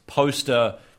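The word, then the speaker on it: Phoster: It's poster, but with a PH. – Poster, I guess. – I think it's a poster, but anyway, PH is silent, dude.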